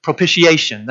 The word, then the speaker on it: propitiation: Propitiation, that's how you pronounce propitiation. I didn't know that until like last week. I didn't, in getting ready for this. I would say propitiation.